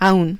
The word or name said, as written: aun